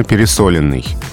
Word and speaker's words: пересоленный: Куда падает ударение в данном случае? Ну вот здесь ее и нет – а ударение падает на «о» и правильно пересоленный.